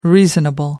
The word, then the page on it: reasonable: American English